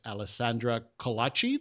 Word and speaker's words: Alessandra Colaci: It's a special guest, Alessandra Colaci.